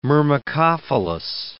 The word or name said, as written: myrmecophilous